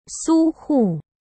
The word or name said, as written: Su Hu